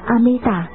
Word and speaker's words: Amita: Chữ A Di Đà nguyên tiếng Ấn Độ đọc là Amita,